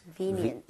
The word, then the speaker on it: convenient: Convenient. – VE에 강세를 짓고 발음하면 되겠습니다. 여러분 같이 한번 발음해 보도록 할게요. VE에 강세 주고요.